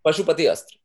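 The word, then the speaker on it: Pashupatastra: I would like to know also, can you tell us about the Pashupastra? Did I say that properly? – Pashupati Astra. Pashupati Astra. – Thank you.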